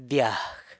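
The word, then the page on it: dyag: San Lucas Quiaviní Zapotec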